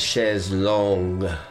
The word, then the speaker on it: chaise longue: No, I think the French term is chaise longue. – Oh. – Long chair. – That's right. Chair. That's right. – Chaise longue, and it's just mispronounced in English.